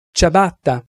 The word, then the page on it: ciabatta: Italian